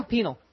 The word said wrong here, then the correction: Pinal: No, Pinal. – Right. – It's pronounced Pinal. – Right. – Pinal, like penalty in soccer.